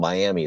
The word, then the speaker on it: Miami: They called it Miami. It's called Miami is the way it's spelled. But Miami, Oklahoma.